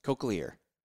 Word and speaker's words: cochlear: And cochlear. – Is that how you say it? – Yes.